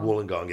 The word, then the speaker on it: Wollongong: Wollongong. – Wollongong, yeah, because okay.